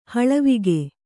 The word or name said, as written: ಹಳವಿಗೆ